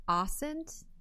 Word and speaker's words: OSINT: And it's pronounced OSINT. – I say OSINT, but I don't know. – Okay. – I say OSINT too.